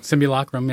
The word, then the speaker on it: simulacrum: You talked about a term which I won't be able to pronounce, but simulacrum. – Simulacrum, yeah.